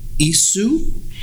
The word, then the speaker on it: Issuu: Okay, so issue. – It's ISSUU. – Spell it again. – ISSUU. Issue. So you create a beautiful handout, PowerPoint handout, or you create a wonderful brochure of yourself, and then you send it to someone.